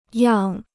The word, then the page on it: 样: yàng